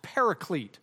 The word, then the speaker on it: paraclete: We did point out last time that the Greek term translated helper is what we pronounce paraclete.